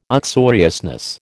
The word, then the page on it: uxoriousness: American English